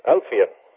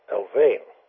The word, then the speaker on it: Alfvén: who I said, Siegfried, how do you pronounce this fellow's last name? It's A-L-F-V-E-N. And Siegfried said, well, that's Alfien. – Alfien, yeah. – Yeah, and well, then I've heard another fellow on a video program call him Alvain.